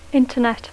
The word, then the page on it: Internet: English